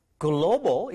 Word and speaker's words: global: WCB English is a global English program. 정답 볼게요. You got it. G-L-O-B-A-L. V가 아닙니다, 여러분. Global에서 입술이 붙어야 되는 발음이 되겠죠.